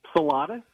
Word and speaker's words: Czulada: Salada. – Salada. All right. C-Z-U-L-A-D-A.